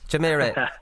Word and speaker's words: Jumeirah: You can pronounce it, Jumeriah Gales. – Close enough. – You say it. I have problems pronouncing even. – Jumeirah.